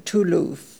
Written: Tooloof

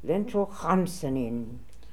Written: Lentrohamsanin

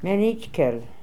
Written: Menitkel

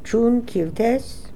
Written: Choon Kil Tez

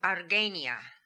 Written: Arguenia

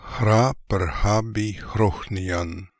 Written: Hraprkhabeekhrokhnian

